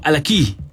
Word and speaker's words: Halaquy: Él es Gerardo, mejor conocido como Alaquí. ¿Lo pronuncié bien? – Sí, claro. Alaquí. – Alaquí. – Alaquí. Así se pronuncia, se escribe H-A-L-A-Q-U-Y.